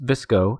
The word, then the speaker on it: VSCO: The term VSCO, pronounced VSCO, girl, refers to a popular subculture among members of Generation Z, consisting of teenagers following certain fashion choices that go along with trends.